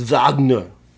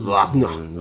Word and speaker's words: Wagner: It's Wagner. – Mm. Their W's are like V's over there. Mm. – Wagner.